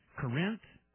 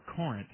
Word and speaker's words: Corinth: Right up there near the Shady Shores exit on I-35 in Corinth or Corinth, Texas, depending on which way you like to pronounce it, just north of Lake Louisville.